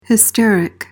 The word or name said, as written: hysteric